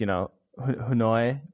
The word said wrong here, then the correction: Hanu: His name is Hanu, Adrian Hanu, which you've pronounced as Hanoi.